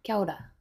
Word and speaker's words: Kia ora: Wait, do I say that right? – Yeah. – Kia ora. – Yeah, that's right.